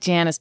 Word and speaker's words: Janus: And then our calendar, what we are on is like the Roman calendar, and January is named after the Roman god Janus, or spelt with a J. – Janus. Janus. – I like Janus better. – Yeah, we're going to go with Janus.